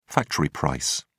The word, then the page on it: factory price: British English